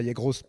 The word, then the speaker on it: Yegros: Alors toi, tu prononces bien, parce que nous, on dit, nous, justement, nous, pour les non-hispanophones d'Argentine, on dit la Yegros pour nous.